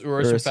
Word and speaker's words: Urus: It's pronounced Urus. Urus. Whatever.